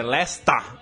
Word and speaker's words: Leicester: Hoje tinha no fim de semana que teve jogo do Leicester em casa, tinha um cachecol que tava L-E-S, tracinho, T-A-H, então é Leicester. É como Leicester.